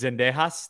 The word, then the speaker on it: Sendejas: And it's Sendejas, that's how you pronounce it.